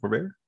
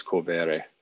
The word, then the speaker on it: Corvere: Am I saying it right? Corvair? – Depends. I think the Italian pronunciation is Corvere.